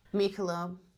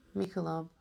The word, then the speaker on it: Michelob: I think it was Michelob. I don't even know how to say it, right? – Michelob. – Michelob? – Michelob.